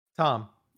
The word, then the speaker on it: Thom: So his name is spelled T-H-O-M. Is that Thom or Tom? – Tom. – Okay. Tom.